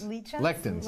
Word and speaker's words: lectins: And then Michael, are you concerned about, is it lichens in legumes? Am I saying this right? – Leeches? I'm concerned about lichens. I like lichens. – Lectins. Lectins? I don't know.